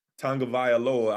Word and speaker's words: Tagovailoa: So that defense going to have to step up and play well next week again because Tua – Tagovailoa.